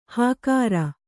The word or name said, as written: ಹಾಕಾರ